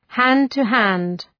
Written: hand-to-hand

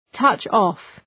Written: touch off

